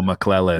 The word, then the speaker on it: McClellan: Hey, it's easier to say that way. See, if you say with our accent, it's tricky. But it's impossible to get wrong if you say it right. McClellan.